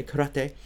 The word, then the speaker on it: karate: Learning karate, if we want to pronounce it in the Japanese way, karate, uh, with Sensei Porta.